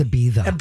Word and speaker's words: Ibiza: And you didn't Say it wrong, Grant. It's Ibiza.